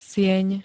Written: sieň

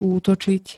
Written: útočiť

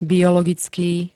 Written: biologický

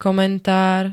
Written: komentár

